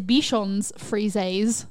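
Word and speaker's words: Bichons frisés: And the plural is Bichons frises. So they Bichon both get an S.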